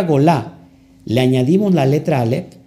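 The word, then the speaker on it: Golá (גולה): Ojo aquí, exilio en hebreo es gola, se escribe gola. Gimel, Bab, Lamet, Hei, Golá.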